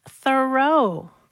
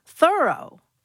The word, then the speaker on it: Thoreau: All this time, I thought his name was pronounced Thoreau, but no. – How is it pronounced then? – Thorough.